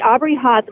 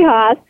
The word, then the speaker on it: Haase: Today's show, I'm really excited for this guest, Aubrey Haas. Am I saying that right, Aubrey? Did I say your last name right? – Yeah, perfect. – Ah, got it. Okay, good. Aubrey Haas, HAASE, is joining us today.